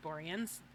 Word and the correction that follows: Boreanaz: David Boreanaz, I don't know. – Oh, God damn it. Am I pronouncing his name wrong? – Yeah.